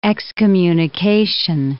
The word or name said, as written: excommunication